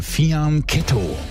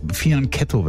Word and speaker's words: Fianchetto: Manche sagen auch Fianchetto, aber das Falsche ist, Fianchetto wird's ausgesprochen.